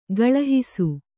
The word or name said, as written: ಕಳುಹಿಸು